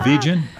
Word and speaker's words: vegan: That soft G. – Right. – In vegetable, vegetation, vegetarian, like how did that become the hard G in vegan? – Everyone says it's hard to do.